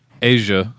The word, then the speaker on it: Eija: Then we have Asia Skarsgård, bullshit. – Spelled E I J A. – How do you know it's pronounced Asia? – How else would that be pronounced?